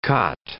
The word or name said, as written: cot